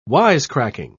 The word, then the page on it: wisecracking: British English